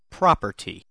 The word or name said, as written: property